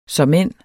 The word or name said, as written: såmænd